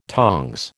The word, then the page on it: tongs: American English